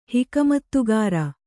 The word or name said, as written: ಹಿಕಮತ್ತುಗಾರ